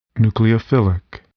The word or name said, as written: nucleophilic